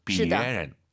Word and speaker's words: 别人: 이거는 경성으로 약간 발음해도 괜찮은 것 같더라고요. 别人.